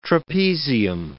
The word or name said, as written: trapezium